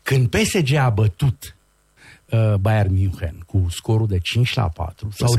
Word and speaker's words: München: Și noi zicem München. Și totdeauna când auzeam München.